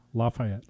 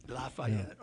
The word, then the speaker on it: Lafayette: Lafayette. – Lafayette.